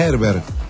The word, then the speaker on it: Herbert: No, es que se pronuncia Herbert. En portugués.